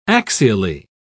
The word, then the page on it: axially: British English